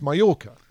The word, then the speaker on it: Mallorca: So we're heading to Majorca or Mallorca, which is pronounced Mallorca.